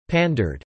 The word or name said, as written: pandered